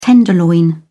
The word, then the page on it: tenderloin: British English